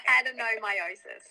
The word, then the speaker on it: adenomyosis: Adenomyosis.